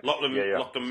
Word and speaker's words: Lachlan: So what was your conversation with, and do I pronounce it Lachlan? – Lachlan Milne.